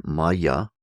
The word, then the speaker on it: Maya: The word Maya is in two halves, ma, which means is not. M-A, Maharishi, and absolute, M-A. M-A, ma, pronounced, means is not. Ya, Y-A. Y-A in this particular context means which is not which is.